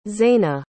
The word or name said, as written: Zaina